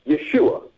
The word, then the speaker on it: Yeshua: Now, keep in mind, I would remind them that Yeshua, okay, and I'll say his name in Hebrew, Yeshua, said we are supposed to pray in his name.